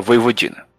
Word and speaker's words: Vojvodina: É, é, é assim, assim é, se escreve Vojvodina, né? Mas no idioma Exato. eslavo o J tem som de I, então fica Voivodina